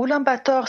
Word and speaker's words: Ulaanbaatar: Et, comment prononce-t-on, euh, parce que le nom de cette capitale m'est toujours un peu étrange, Oulan Bator, comment on le dit en mongol euh, alors Ulaanbaatar, c'est une appellation donc un peu euh, occidentalisée, mais nous on dit Ulaanbaatar.